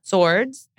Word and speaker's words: swords: Swords. And I always pronounce that W. I have to consciously not say swords. I want to say swords.